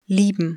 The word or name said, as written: lieben